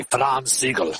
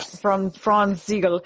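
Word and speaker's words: Franz Sigel: Let's say it right. I say Franz Siegel. – Franz Siegel was one of their, I should be able to say it right because I'm like half German.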